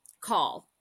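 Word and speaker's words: cail: It's pronounced call in Gaelic.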